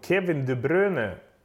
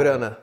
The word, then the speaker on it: De Bruyne: Kevin De Bruyne, han uttalar så. Själv. – Är vi klara på det nu? – Ja, han har sagt det och vi försöker här. Bruyne.